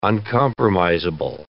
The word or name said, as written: uncompromisable